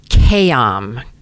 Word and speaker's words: Khayam: Originally, their last name was Kayam. There's a couple of silent letters here, K-H-A-Y-A-M.